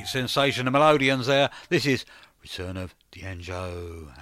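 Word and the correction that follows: Django: and this is Upset Us. No, I think it's Danjo, isn't it? I pronounced it wrong.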